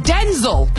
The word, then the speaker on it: Denzel: He says it's pronounced Denzel, but even he calls himself Denzel.